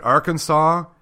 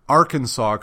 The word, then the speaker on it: Arkansas: Arkansas, you have to pronounce Arkansas correctly. – Can't call it Arkansas. – Or Arkansas. – Saws. – Saws.